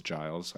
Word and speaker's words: Giles: I love his potential, and I also said his name wrong. It's Giles. I always do that. But it's a soft G.